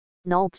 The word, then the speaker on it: Nope: Nope GT100 Nope 发音是 No，是一间位于爱沙尼亚的新创公司。